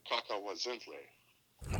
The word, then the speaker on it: Cacahuazintle: The Abasolo brand is committed to supporting local farmers and strictly using Mexican heirloom corns, primarily a varietal called Cacahuazintle. – Wow, nice pronunciation.